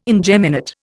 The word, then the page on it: ingeminate: Indian